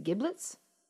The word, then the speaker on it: giblets: You can do it with sausage and the and the is it giblets? – Yeah. – Yeah, Giblets. that that's a soft G.